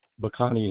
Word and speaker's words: Bacani: Bacani is my last name. B-A-C-A-N-I.